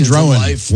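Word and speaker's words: Drohan: Similar players, similar looking guys, similar Shane Drouin, one syllable and then two. – Yeah. – Brandon Sproat, two syllables then one. But Sproat and Drouin both have the long O sound, and it's like I just can't keep the two.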